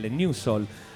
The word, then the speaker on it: Nu Soul: però adesso quando si scrive New Soul e non New N-E-W, ma purtroppo va detto N-U-S-O-U-L, New Soul, scrivete così,